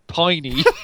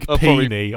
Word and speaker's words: Piney: His US birthplace was Big Piney, Wyoming. – Big Peeny, I want to call it, for obvious reasons. – Piney, peeny, butchered. – I think it's piney, but you're right.